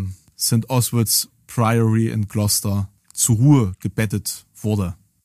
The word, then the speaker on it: Gloucester: Ich hab's, ich hab's, ich hab's gerade auch äh, falsch ausgesprochen, ist natürlich Gloucester, ja, also.